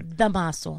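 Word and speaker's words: Dámaso: Yeah, Tomaso Perez Prado. – Oh, okay. That's not bad. Tomaso Perez Prado? – No, okay. – Damaso. – Oh, it's not Tomaso. – No, Damaso.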